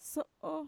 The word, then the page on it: so'o: Mixtec